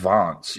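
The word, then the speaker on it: Wanz: 34 years ago today, Chicago, Illinois, Nick Bockwinkel defeated Otto Wants to win the AWA Heavyweight Championship. I had to mention Nick Bockwinkel in this episode. – I believe it's pronounced Vance, even though it's with a Vance. W.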